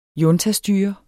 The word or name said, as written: juntastyre